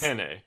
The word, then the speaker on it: penne: It's like, it's penne. – Yeah, penne. It's more, the first syllable is the emphasis.